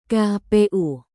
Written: kpu

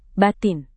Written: batin

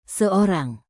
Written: seorang

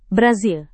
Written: brasil

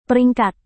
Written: peringkat

